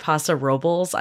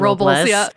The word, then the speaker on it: Paso Robles: I just did a trip to San Luis Obispo and Paso Robles. I've learned that's how you pronounce it. Yeah, not Robles, which is what I and everyone else calls it.